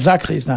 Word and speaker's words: sakrist: Alors ça s'écrit S-A-K-R-I-S-T. Et ici, on prononce sacriste, hein. Le S ici, il y a toujours, souvent tendance à donner un Z, quoi.